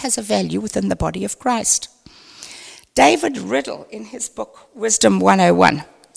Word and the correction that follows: Riddell: I think Riddell, is that the correct pronunciation? Sorry, not Riddle, Riddell.